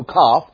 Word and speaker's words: Kaf: This consonant is often spelled with a Q, same sound though, Kaf. In fact, that K sound that you hear, this letter, Kaf, is one of three K sounds in the Hebrew language.